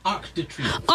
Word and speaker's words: Arc de Triomphe: Is that my Arc de Triomphe.